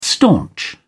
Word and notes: staunch: British English

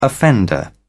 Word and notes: offender: British English

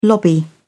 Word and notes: lobby: British English

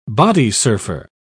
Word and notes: bodysurfer: British English

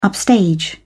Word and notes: upstage: British English

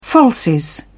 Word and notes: falsies: British English

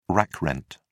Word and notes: rack rent: British English

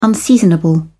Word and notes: unseasonable: British English

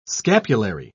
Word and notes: scapulary: British English

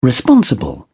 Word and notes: responsible: British English

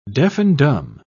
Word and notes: deaf-and-dumb: British English

